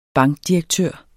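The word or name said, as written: BANKDIREKTØRER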